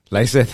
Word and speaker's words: Lisseth: Lisseth. Sí, por ejemplo, con mi nombre, se escucha totalmente diferente... en español que en inglés.